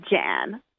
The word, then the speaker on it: Ghann: It's Jameela Jan.